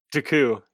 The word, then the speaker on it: Dooku: Dooku, yeah. – Yeah, something like that. Yeah. He puts that emphasis on that last syllable.